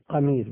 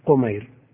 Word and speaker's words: قمير: وزوج مسروق، زوجة مسروق تابعي الجليل، زوجته اسمها قمير، قمير بفتح القاف، ومن عداها كله بالتصغير قمير. وزوج مسروق قمير صغر سواه، يعني وزوج مسروق قمير، انتهى في الجملة.